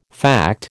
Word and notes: fact: American English

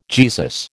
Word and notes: Jesus: American English

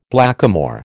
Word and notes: blackamoor: American English

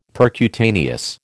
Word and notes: percutaneous: American English

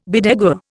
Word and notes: bedeguar: Indian

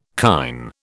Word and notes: kine: British English

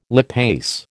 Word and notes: lipase: American English

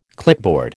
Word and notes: clipboard: American English

